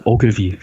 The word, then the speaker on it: Ogilvy: Äh, Ogilvy spricht man ihm aus und da war ich auch überrascht.